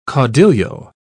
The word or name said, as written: caudillo